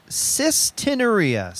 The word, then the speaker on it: cystinuria: What is cystinuria? – You're asking the wrong damn guy. – I'll look it up. How do you say it? – It's called cystinuria. C-Y-S-T-I-N-U-R-I-A.